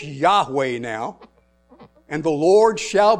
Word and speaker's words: Yahweh: And if you're from the South, it's Jehovah. But I pronounce it Yahweh. Yahweh.